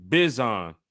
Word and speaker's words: Bijan: Aaron Parkinson, he got the pronunciation. He said, it's Bijan. – Bijan.